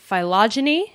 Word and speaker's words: phylogeny: It's all about the phylogeny. Am I saying that right? – Yes.